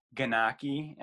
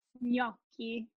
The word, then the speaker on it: gnocchi: So some people say ganaki, and then how should you really pronounce it? – Gnocchi. Gnocchi. Yeah, the GN is a very tricky sound for a lot of people.